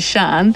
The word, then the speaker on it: Cyan: That's how you say it, Sean. – Cyan. It could be Cyan. It's a colour, isn't it? Cyan. – Yeah. – C-Y-A-N.